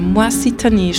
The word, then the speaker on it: Moisitani: Jedenfalls, also Rulot ist cool, Goran Kovacevic, Peter Lenzin, aber mein Highlight ist schon gerade Moisitani, spricht man so aus. Man schreibt Moisitani.